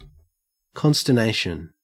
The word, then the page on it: consternation: Australian English